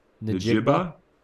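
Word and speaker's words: Njigba: Najigba.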